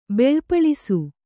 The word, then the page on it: ಬೆಳ್ಪಳಿಸು: beḷpaḷisu